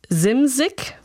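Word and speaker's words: Szymczyk: Ihr Produzent Bill Simzik, so wird er ausgesprochen,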